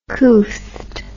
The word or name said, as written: couthed